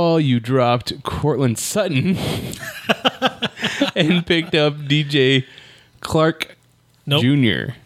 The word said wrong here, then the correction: Chark: Nope. You have to say it right, man. – DJ Clark. Do. – You have to say DJ Chark.